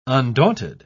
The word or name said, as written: undaunted